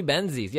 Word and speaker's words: Benzies: Leslie Benzies, yes. It's like Jesse Hayes. – It's like Benzines. It's pronounced weirder than it looks, but yes.